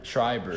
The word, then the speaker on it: Schreiber: Schroudner. I can't say his name. So filthy. So filthy. I'm sorry. That man is disgusting. – Wait, from what team? – The Archers. Tom Schreiber. – Tom Schreiber.